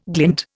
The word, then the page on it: glint: Indian